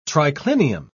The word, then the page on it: triclinium: British English